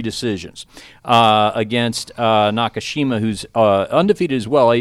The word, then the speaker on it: Nakashima: Nakashima. – Yes, James Nakashima for the LFA Legacy Fighting welterweight title.